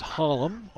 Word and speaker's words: Holm: His last name is spelled H-O-L-M, which rounds it with a like a U in there. It's Hullum on the carry.